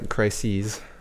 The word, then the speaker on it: crises: Crisis here, it's in a plural form. Mm-hmm. You pronounced it in a special way. – Right, yeah, crises. – Right. If it's singular, how would you pronounce it? – Crisis. – 네, right, so, 방금 들으셨어요? Could you repeat that for our listeners? Closer to the mic. – Right, so one crisis and two or more crises.